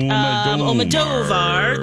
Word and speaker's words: Almodóvar: There's an accent on the O, so I'm like, wait. Almodovar. There we go.